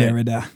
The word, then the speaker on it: Derrida: And then you've got Derrida, correct? Did I say that right? – Derrida, yeah.